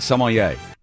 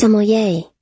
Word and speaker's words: sommelier: Sommelier. – Oh, that's weird. That's sommelier. – That's right. That's how you say it.